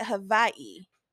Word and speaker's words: Hawaii: You know, you actually pronounce it Hawaii. – Hawaii? – A lot of people don't know that. The W is a V. Hawaii.